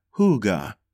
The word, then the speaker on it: hygge: Hygge, pronounced hygge, is a mysterious Danish word with many meanings.